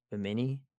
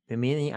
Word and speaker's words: Bimini: Bimini. B-I-M-I-N-I. Bimini. – Sure. Yeah. Sounds right to me. – That's not how they pronounced it. Hold on a second. – Bimini. – Bimini. Thank you.